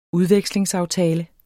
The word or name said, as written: udvekslingsaftale